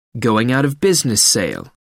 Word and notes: going-out-of-business sale: American English

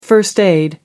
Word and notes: first aid: American English

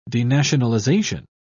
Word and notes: denationalisation: British English

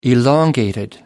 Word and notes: elongated: American English

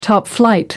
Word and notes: top-flight: American English

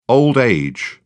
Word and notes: old age: British English

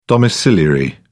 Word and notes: domiciliary: British English

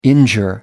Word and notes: injure: American English

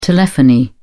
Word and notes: telephony: British English

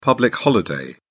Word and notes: public holiday: British English

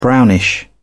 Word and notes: brownish: British English

